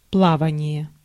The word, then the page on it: sailing: American English